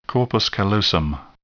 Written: corpus callosum

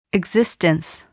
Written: existence

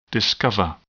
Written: discover

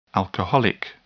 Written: alcoholic

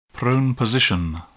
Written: prone position

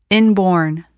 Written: inborn

